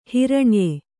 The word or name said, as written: ಹಿರಣ್ಯ